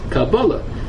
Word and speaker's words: Kabbalah: The Rashi says the pronunciation is Kabbalah.